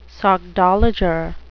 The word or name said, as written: sockdolager